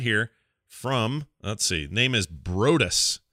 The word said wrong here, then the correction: Broadus: Oh, no, Broadus, he says. He gives me a pronunciation table thereafter.